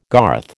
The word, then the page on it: garth: American English